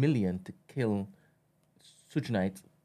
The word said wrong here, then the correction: Suge Knight: Am I saying that properly? Suge Knight? – Suge Knight.